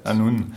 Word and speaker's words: Annwn: Comment on dit Anouen ça s'écrit A-N-N-W-N, mais ça se prononce Anoun en fait. Anoun.